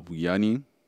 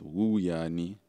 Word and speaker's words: Vuyani: Um, for those who don't know me, my name is Vuyani Bila. Uh, Vuyani Bila, pronounced Wuyani, uh, spelled V U Y A N I.